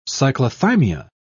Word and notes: cyclothymia: British English

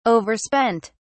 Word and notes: overspent: British English

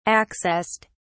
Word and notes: accessed: British English

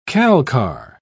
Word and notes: calcar: British English

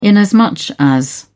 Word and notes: inasmuch as: British English

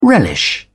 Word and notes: relish: British English

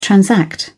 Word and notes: transact: British English

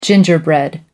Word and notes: gingerbread: American English

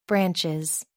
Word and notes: branches: American English

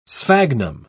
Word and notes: sphagnum: British English

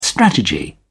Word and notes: strategy: British English

